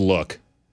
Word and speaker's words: lewk: That is just look. – Luck? – Look. – Look. – Look. – L-E-W-K. – Look, yeah. It's just a dumb spelling of you remember you used to say, oh, that's a look.